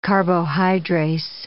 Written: carbohydrase

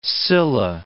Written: Scylla

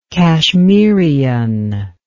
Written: kashmirian

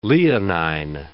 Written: leonine

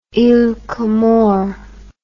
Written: iles comores

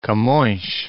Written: Camoes

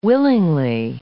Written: willingly

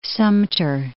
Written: Sumter

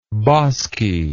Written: boskiness

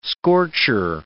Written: scorcher

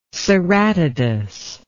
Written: ceratodus

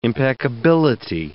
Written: impeccability

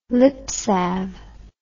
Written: lipsalve

